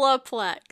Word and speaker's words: Olaplex: So I need to go and I I like oral oral plex, but it's not like. Oh, am I saying it wrong? Olaplex. Say it again. Olaplex.